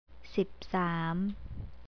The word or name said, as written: sipsam